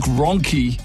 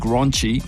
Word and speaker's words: Ronchi: But twice in the news, she's been mispronouncing a couple of names here. It's Luke Ronchi, who was the former Black Caps batting coach. He's been replaced. He called him Luke Ronchi.